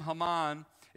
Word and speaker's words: Haman: Now, in the pronunciation, which I tend to wreck and ravish, his name, Haman, is really close to Hamon in Hebrew, which literally means confusion.